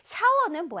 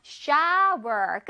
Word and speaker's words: shower: T-t-t-t-t-t. Toilet. One more time. Toilet. 좋아요. 샤워는 뭐야? 어, 샤워? 아니죠. Shower. 끝에 R 발음 살려주기로 했죠?